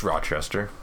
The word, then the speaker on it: Rochester: It's Rochester. – Yeah. – It's not rock. You don't pronounce the C with a heavy C or a hard C. – So it's raw, like...